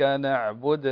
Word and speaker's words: نعبد: لا أشخ عبد الله، شوف قرأت إيش، اسمع الفرق بينهم، إياك نعبد وإياك نستعين. الفرق إياك نعبد، دو ضم الشفتين عند النطق بالدال لكن لا تبالغ في الضم، إياك نعبد وإياك نستعين.